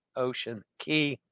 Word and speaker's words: Ocean Cay: I like Ocean Cay the best. Ocean Key the best. Sorry, Key and K. Royal Caribbean never should have changed the pronunciation. It should all be Key.